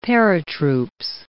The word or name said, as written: paratroops